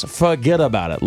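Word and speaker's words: fuhgeddaboudit: Yes, forget about it.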